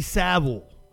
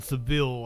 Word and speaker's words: Savile: Jimmy, Jimmy I, I somebody corrected me the other day on the video, and I'm sorry I'm an Appalachian. Jimmy Savile. Jimmy Savile. I, did I say it right this time? Jimmy Savile. I think I said Seville last time.